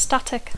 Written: static

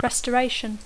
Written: restoration

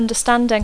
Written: understanding

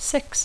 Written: six

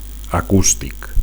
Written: acustic

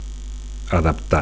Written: adaptar

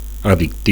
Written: addictiu

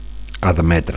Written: admetre

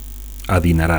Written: adinerat

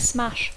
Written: smash

